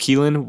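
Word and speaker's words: Kelan: And also, it's pronounced Keelan.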